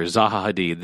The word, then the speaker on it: Zaha Hadid: Zaha Hadid.